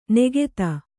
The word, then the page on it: ನೆಗೆತ: negeta